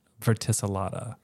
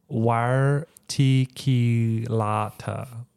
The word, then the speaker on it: verticillata: So it has a guide pronunciation for specific epithet, verticillata, which is how I pronounce it. – Yeah. – Is what it's listed on here. But it does say the classical method is wire ticillata.